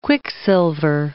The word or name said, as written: quicksilver